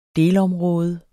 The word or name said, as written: delområde